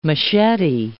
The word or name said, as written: machete